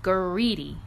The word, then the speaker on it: Guridi: Not Greedy. It's Greedy. I don't know if I'm pronouncing it right. It's spelled G-U-R-I-D-I. Greedy.